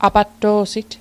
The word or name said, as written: apatto'sit